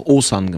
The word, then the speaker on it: Osan: Und da viele meiner Fans ein Problem haben, diesen Namen zu googeln, zu schreiben und irgendwie zu finden, haben wir aus dem Osan einfach Osan gemacht. Mhm. O-S-A-N. – Mhm. Und das ist doch viel einfacher für mich zu sprechen. Ja. Und Osan Yaran spricht sich natürlich für den Berlin-Brandenburger ganz hervorragend.